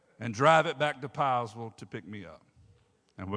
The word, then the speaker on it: Piesville: You don't say it like it's spelled. You say Piesville. I know. I've been around here long enough. P-I-E-S-V-I-L-L-E, Piesville.